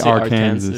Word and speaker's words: Arkansas: I never knew how to pronounce that name. – Yeah, it should be Arkansas. Yeah. – Because you have like Kansas, but Louisiana.